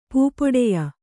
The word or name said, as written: ಪೂಪೊಡೆಯ